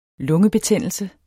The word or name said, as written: lungebetændelse